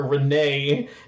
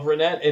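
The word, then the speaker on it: Renet: no, it's Renette, it's Renette. You pronounce the T.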